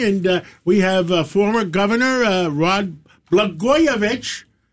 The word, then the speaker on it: Blagojevich: Well, Blagojevich rhymes with Katzimatidis, and in California, I believe they're stealing votes.